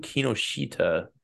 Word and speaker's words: Kinoshita: Starting off, Yusaku Kinoshita. 6-1 coming off contender. Taking on the boy out of Eugene, Oregon, Adam Fugit. – So, -320. How do you say his name? Yakuza? – Kinoshita.